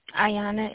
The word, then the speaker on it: Ayana: Ayana, A-Y-A-N-A Robinson. – Can you please spell it for me? – A-Y-A-N-A.